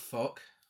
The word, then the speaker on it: Fouke: Yeah, the group heads for an area near Boggy Creek, close to the town of Fouke. It's spelled F-O-U-K-E.